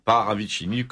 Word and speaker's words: Paravicini: est un musicien que vous trouvez sur Internet, Paravicini, comme ça se prononce, C-I-N-I,